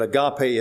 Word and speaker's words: agape: it's always translated from the Greek word agape, or agape, whichever way you prefer to pronounce it. But agape is correct.